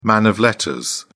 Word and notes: man of letters: British English

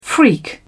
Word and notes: freak: British English

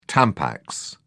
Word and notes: tampax: British English

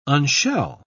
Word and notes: unshell: British English

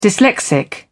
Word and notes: dyslexic: British English

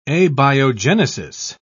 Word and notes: abiogenesis: British English

